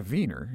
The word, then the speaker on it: Wien: I've never been. Wien. Spelled with a W. – Correct. – W-I-E-N. – Pronounced Wien. That's where we get a V.